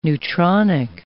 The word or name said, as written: neutronic